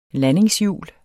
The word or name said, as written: landingshjul